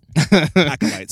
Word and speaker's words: acolyte: Like, I thought she was like an alley cat, like a Sith alley cat or something. – An alley cat? – Acolyte. Sorry, not alley cat. Sorry, I said it wrong.